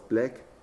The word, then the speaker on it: Blake: Wie spricht man die eigentlich aus? Black. – Ja. – Black, wie genau.